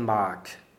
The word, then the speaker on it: mag: At the end of the second verse, we get a G that's spoken like a K, as he said in mag.